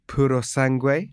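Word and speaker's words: Purosangue: Puro Sangue.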